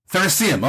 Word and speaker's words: Theracium: So when you sat down to write that first novel, is it Theracium? Is that how you say it? – Theracium. – Oh, I was bound to get it wrong, wasn't yes. I? – It's sort of like obviously based on like Colosseum, Theracium.